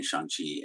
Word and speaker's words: Shang-Chi: It's always been Shang-Chi, and they have commented about how nice it was that people so quickly moved to the correct mm-hmm.